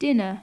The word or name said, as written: dinner